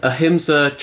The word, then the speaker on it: Ahimsa: So the other thing that the other book that you've got is the tell me if I'm pronouncing this wrong, the Ahimsa training manual. – Yes, good job.